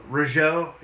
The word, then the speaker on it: Rougeau: Rougeau.